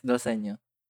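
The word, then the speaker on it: dal segno: Dalsenyo. Is that how you pronounce it? – Yes, Dalsenyo.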